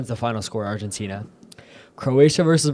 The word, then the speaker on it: Croatia: Yeah, they're either like, going against, what is that? Croata? How do you say that? – Croatia? – Oh. – No, we're good. It's Croatia.